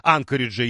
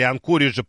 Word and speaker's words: Анкориджа: Виталий ждет теперь дух Шэньчжэня вслед за духом Анкориджа или Анкориджа. По-разному люди ставят ударение.